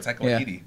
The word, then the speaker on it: Taika Waititi: Taika Waititi? – That's what I know. Taika Waititi. – Yeah. There you go. – I know. – All right. – Taika Waititi.